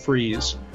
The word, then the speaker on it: Fries: F-R-I-E-S, like fries, except he pronounces it freeze.